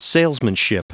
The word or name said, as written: salesmanship